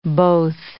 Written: both

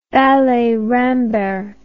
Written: Ballet Rambert

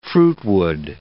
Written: fruitwood

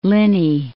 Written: Linnhe, Loch

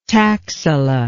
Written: taxila

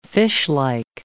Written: fishlike

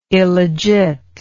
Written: illegit

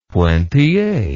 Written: pointille

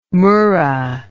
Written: murra

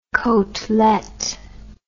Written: cotelette

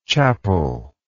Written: chapelry

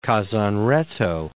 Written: Kazan Retto

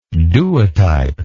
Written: duotype

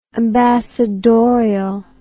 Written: ambassadorially

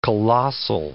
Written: colossal

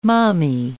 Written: mommy

